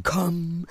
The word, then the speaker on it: Comb: Comb. – Comb. Das B ist stumm. Comb.